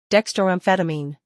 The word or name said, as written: dextroamphetamine